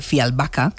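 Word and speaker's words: Fjällbacka: Pues resulta que los crímenes de Fialbaca, que es como se pronuncia, ella nos lo dijo así la última vez, están y vuelven esos protagonistas de todo esto y también, vamos a añadir, de misterio, de una cuenta pendiente del pasado.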